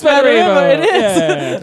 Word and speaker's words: Smederevo: It's Smederevo. – That's Serbian for smegma. – Smegma. – Smederevo.